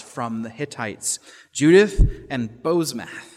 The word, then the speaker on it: Basemath: I had to look up the Hebrew for how to pronounce that name, by the way. It's kind of an odd name. You can call her Basemath if you want, because that's what it looks like.